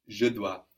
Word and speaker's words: dois: Je dois. And we have a silent S there, no? It's DOIS. Just like in tu dois, how was he must? – Il doit.